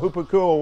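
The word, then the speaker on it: Hupakuo (ὑπακούω): Virtually every time he talks about obeying the gospel, every time I found, he uses the Greek word hupakuo. It's spelled H-U-P-A-K-U-O. That's how you transliterate it so you can pronounce it in English. Hupakuo.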